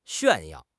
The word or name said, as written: 炫耀